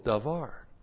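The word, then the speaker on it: davar (דָּבָר): So this is the verb, davar, and from that verb is a noun, D-A-B-A-R, notice, D-A-B-A-R, D-A-B-A-R, what's the difference? Comets pathak, that's the verb, comets, that's the noun. But you would pronounce them both davar.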